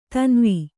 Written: ಧನ್ವ

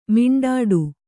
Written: ಮಿಂಡಾಡು

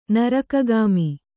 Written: ನರಕಗಾಮಿ

